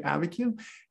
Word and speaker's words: Abiquiu: No, I'm not mispronouncing Albuquerque. It's really named Abiquiu.